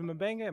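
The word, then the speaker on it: M'Benga: I will say Mabanga, is it Mabanga? Am I saying that right? – Yeah.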